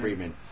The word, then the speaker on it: Friedman: And we had this really fantastic interview with Nat Friedman. Am I saying his name right? – Yes, Nat Friedman.